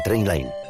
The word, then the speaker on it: Trainline: Se escribe mejor precio garantizado. Se pronuncia Trainline.